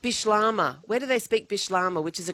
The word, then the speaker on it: Bislama: Is that Bish is that V or B? – B. Bish. So it's spelled Bislama. But from when I went there, my memory is it's Bishlama. So a Creole language.